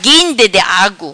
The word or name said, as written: Guinde Di Agu